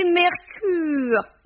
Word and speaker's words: Mercœur: Et puis, assez vilain pour une femme chic, ça s'écrit Mercœur. Il faut prononcer Mercure, disait-elle, la bouche en cul de poule.